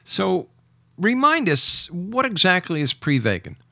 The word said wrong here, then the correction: Prevagen: Well, technically it's pronounced Prevagen,